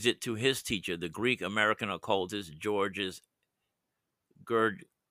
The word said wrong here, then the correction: Gurdjieff: Anyways, it's a hard to pronounce name. Gurdjieff. Occultist George Gurdjieff considered the Enneagram a symbol of the cosmos, and he never made any connection with it to personality types.